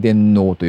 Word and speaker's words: 電脳: 株式会社電脳交通は商用車両の運行管理システムを構築できる開発環境、電脳モビリティ、カッコ仮。電脳はアルファベットで DENNO ですね。これで電脳と読むそうなんですが、こちらをオープンソースとして GitHub 上に公開しました。